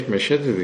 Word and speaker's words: مشهد: مشهد بخواد درست تلفظ صحیحش مشهد دیگه، ما به چه مشهدم، مشهد هم مشهده.